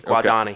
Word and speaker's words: Guadagni: There's a G in there. Yeah. Just ignore the G. Guadagni.